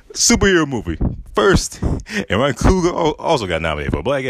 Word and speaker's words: Coogler: Because when you look at Black Panther, directed by Ryan Coogler, Yeah, that's how you say his name, Ryan Coogler.